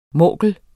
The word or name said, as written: morkel